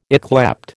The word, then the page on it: yclept: American English